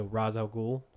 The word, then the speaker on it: Ra's al Ghul: So the way they pronounce it is the way I'm going to pronounce it. So Ra's al Ghul is how it's pronounced.